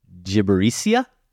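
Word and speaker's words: Gibberitia: Now, Josh, I did some work here to try to figure out how to pronounce this word. It's Ernest and Celestine, A Trip to Gibericia. That's what I heard online. – I'm going to say you're correct.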